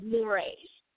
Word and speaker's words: mores: or it's also called mores, is how it's pronounced.